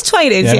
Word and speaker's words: Twinings: Is it Twinings? – Tea's good. It is Twinings.